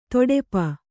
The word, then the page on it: ತೊಡೆಪ: toḍepa